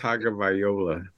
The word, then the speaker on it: Tagovailoa: Tua Tagovailoa. – I knew I said it wrong, babe. – What did you say? Tagovailoa? – Tagovailoa.